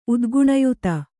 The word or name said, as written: ಉದ್ಗುಣಯುತ